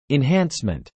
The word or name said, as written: enhancement